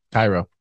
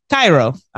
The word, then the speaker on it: Cairo: Did I pronounce that right? – Cairo. – Cairo.